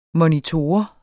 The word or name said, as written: MONITORER